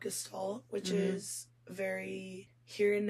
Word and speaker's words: Gestalt: It's spelled Gestalt. – Okay. – It looks like just salt, but it's Gestalt.